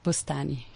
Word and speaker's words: Boustani: Raja.bustani, B-O-U-S-T-A-N-I. – Okay, do that again, Raja. – Bustani.